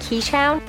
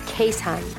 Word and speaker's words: Keeshond: The way Keeshond is spelled, it looks like Keeshound, but the proper pronunciation is Keeshond.